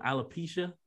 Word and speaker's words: alopecia: I'm going to pronounce it wrong. Alopecia? Is that how you? – Yeah, that's it.